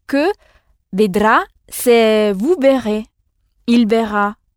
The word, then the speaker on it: vedrà: Vedra, c'est ça. Il ne faut pas oublier d'insister sur la dernière syllabe, sur le « ro » et le « ra ». Ro et ra.